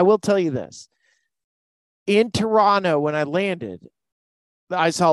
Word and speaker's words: Toronto: They don't pronounce the last T in Tor- Toronto. So when I was heading into Toronto, there was so many different advertisements for Blue Jays.